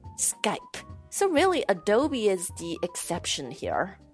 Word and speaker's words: Adobe: Adobe, 啊，这个因为你看它的拼法 A D O B E，可能很容易念成 Adobe。 – Adobe, yeah. – 是吧？确实，按照英语发音的规则规律来讲，应该是 Adobe。但其实这个软件公司，它这个品牌名叫 Adobe。